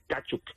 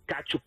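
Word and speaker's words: Tkachuk: La mejor arma ofensiva, por supuesto, es Kachuk. Se pronuncia así, tiene una T atravesada, Caferro, perdóname. – Tranquilo, sí. – Kachuk. Se pronuncia como, es TKA, entonces es como Kachuk, algo así.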